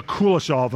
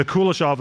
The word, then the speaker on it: Kuleshov: This effect of filmic editing has been called the Kuleshov effect. And Morgan, am I saying this correctly? Is this right? Have you heard of this? Yeah, okay, so the Kuleshov effect, okay? The Kuleshov effect, and it's had an enormous influence on filmmakers, including Alfred Hitchcock, Stanley Kubrick, and Francis Ford Coppola.